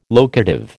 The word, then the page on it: locative: American English